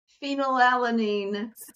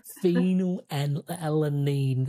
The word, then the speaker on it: phenylalanine: Phenylalanine. – Phenylalanine.